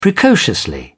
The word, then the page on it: precociously: British English